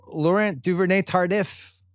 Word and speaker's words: Laurent Duvernay-Tardif: Right guard Laurent Duvernay-Tardif. Is that how you say it? – Yeah, you nailed it.